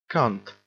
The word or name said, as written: cunt